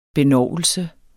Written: BENOVELSEN

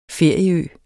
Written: ferieø

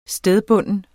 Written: stedbunden

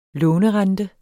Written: lånerente

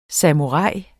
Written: samurai

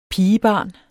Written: pigebarn

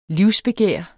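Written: livsbegær

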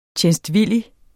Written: TJENSTVILLIGT

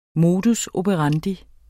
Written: modus

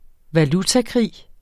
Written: valutakrig

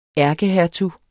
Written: ærkehertug

